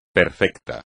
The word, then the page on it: perfecto: espagnol